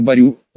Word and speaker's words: Барю: И по рекомендации миссис Хиггинс в доме поселилась гувернантка мадам Барю, ударение на «ю», ваша светлость.